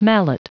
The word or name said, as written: mallet